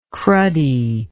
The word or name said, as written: cruddy